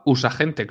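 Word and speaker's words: USAgent: Ah, vale, es que yo a ese personaje lo conozco de los cómics, de, yo lo llamaba Usagente, claro, como está escrito, yo cada uno lo pronuncia como quiere, y yo siempre lo he llamado Usagente.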